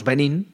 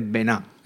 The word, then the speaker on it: Benin: Eso es, que lo que iba a comentar, que nosotros decimos Benín, que es el nombre de, pero como es un país de, bueno, una colonia francesa, pues se dice Benin. – Exactamente, lo pronuncian en francés.